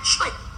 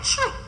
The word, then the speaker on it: Twi: Good African word that come from a nice African language named Chwi. Do you hear it named Chwi? Yeah, man. It's spelled T-W-I, but they pronounce Chwi.